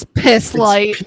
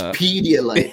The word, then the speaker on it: Pedialyte: It's piss light. – It's Pedialyte. – Yeah, emphasis on the P for sure.